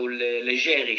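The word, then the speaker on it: Leggeri: Fabrice Legeri or Legeri, because I think his ancestries are Italian. So Legeri should be the right pronunciation.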